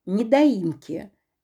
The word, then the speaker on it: недоимки: Для тех, кто с этим не сталкивался, скажу, что пенни считают в размере 1/300 ставки рефинансирования за каждый день просрочки от суммы недоимки, верное ударение на третий слог, за первые 30 дней.